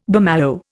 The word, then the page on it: bummalo: Indian